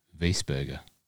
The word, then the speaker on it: Wiesberger: W-I-E-S. – Okay. That would be Weisberger. W-I-E, the I before the I after the E after the I would make a E sound in the German, Austrian German language. Weisberger, not Weisberger, Weisberger.